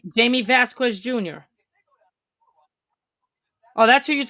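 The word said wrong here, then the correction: Jaquez: How did you say it the first? Jacquez. Okay, yeah, Jamie Jacquez Jr., yeah.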